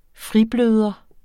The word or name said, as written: fribløder